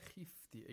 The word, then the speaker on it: khifti: Fa'idha khifti. Again, the khwa should be pronounced with the lowest level of tafkhim. Khifti. We shouldn't say khifti. Khifti is incorrect,